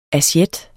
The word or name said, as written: asiet